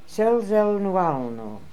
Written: Selzelnualno

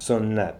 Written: Sooniat

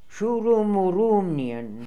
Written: Shooroomooroomnian

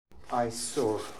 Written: Aisor

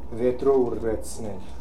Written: Vietro yretznel

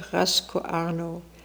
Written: Rascooarno

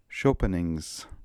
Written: Chopinings

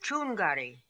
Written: choongary

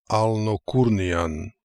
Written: Alnokoornian

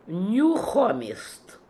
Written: Nooxhomist

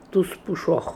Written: Toospooshokh

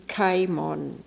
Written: Kaimon

